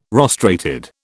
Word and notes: rostrated: British English

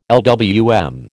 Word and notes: LWM: American English

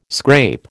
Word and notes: scrape: American English

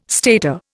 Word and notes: stater: Indian

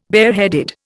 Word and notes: bareheaded: Indian

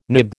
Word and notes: nib: American English

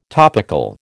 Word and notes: topical: American English

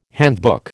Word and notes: handbook: American English

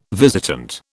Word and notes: visitant: British English

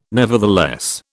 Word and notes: nevertheless: British English

